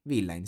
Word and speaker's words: Rose Villain: E ha detto Piango sulla Lambo di Gue Pequeño e Rose Villain, se si pronuncia così, perché effettivamente non è un artista che seguo molto questa, però a cui devo fare i Villain, sì, l'ho detto bene.